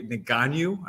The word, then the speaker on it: Ngannou: You know, we got this big boy, Ngannou. I might pronounce his name wrong. – Yeah, close enough.